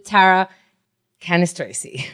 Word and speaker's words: Tara Cannistraci: You're about to fall in love with Tara Cannistraci.